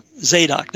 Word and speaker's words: Zadok: They were from the righteous seed of the priesthood, the Tzaddoks, if I'm pronouncing it right. – Zadok, there we are.